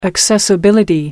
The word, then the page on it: accessibility: British English